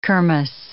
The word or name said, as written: kermess